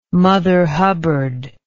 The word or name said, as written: mother hubbard